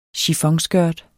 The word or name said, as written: chiffonskørt